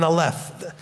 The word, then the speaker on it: Aleph: The accent's on the wrong syllable. It's an Aleph.